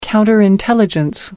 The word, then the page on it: counterintelligence: American English